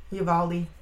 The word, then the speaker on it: Uvalde: Uvalde? Uvalde? Yeah. – Uvalde.